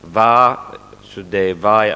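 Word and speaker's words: Васудевая (वासुदेवाय): Последний слог почти не произносится, потому что это короткий слог. Васудевая, а не длинный слог.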